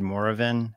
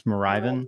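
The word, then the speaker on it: Moravian: It's pronounced Mariven, not however you said it. The Mariven Germans. Did I pronounce that wrong? – Oh, the Germans. – How do you pronounce that? – Sorry, I guess. – I think we said Moravin. It's Mariven.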